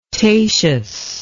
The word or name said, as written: tatius